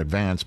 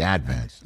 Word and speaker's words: Advance: One would look at that erstwhile publication and call it the Staten Island Advance, but no, in Staten Island, we always put the accent on the first syllable, the Advance.